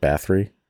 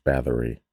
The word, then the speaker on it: Bathory: Is it Bathory? – Bathory. – Am I saying it wrong then? Bathory? It's almost like I'm pronouncing the O a little too much. Bathory. – Yeah, I think it's, I always hear it Bathory.